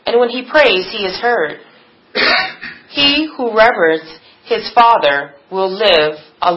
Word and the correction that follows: reveres: Okay, so by the way, I would pronounce that reveres. That's just one of those weird words that looks weird when it's written out.